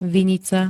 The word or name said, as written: VINICA